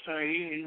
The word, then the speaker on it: Ayin: And he says, he's pronounced Ayin with a slight nasal twang, which means the eye, referring to the eye of Shiva, said to be atrophied into the pineal gland.